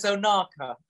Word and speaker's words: Ohnaka: You make it sound like knackers. – yeah, yeah. Sorry, yeah. I, well, yeah Sam said I was like too close to knackers. I'm not sure. Oh, you, like being in the sun, don't you? That's better. Knackers, yeah, I'm just calling you knackers from now on. – class pronunciation is Ohnaka.